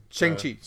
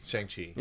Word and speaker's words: Shang-Chi: Shi-Chan or however you say his name. – Shang-Chi. – Shang-Chi. – Yeah, Shang-Chi.